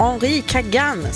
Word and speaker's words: Henri Kagan: Så kan jag också få utrymme att rätta mitt uttal här då. Henri Kagan skulle det vara från Frankrike som vann. Och Kenzo Soai. Jag sa väl något helt annat förut.